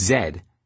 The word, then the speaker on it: Z: He said his name was Zed, which, he explained, was a nickname taken from his first initial, Z, which is pronounced Zed in much of the English-speaking world.